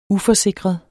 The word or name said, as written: uforsikret